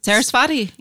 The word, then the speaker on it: Saraswati: It's called The Roots of Saraswati? – Saraswati, yeah.